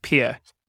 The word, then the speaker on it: Pierre: It's spelled Pierre, but it's pronounced Pierre.